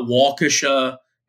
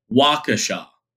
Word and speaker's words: Waukesha: The town that is that has Catholic Memorial in it, it's not Waukesha, it's Waukesha.